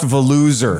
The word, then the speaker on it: Volusia: Volusia? – It's the best Volusia. – It's pronounced Veluser. – Volusia. – Let's just pronounce it right. Ever. Yeah, there's an implied R at the end of that.